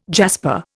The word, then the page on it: jasper: Indian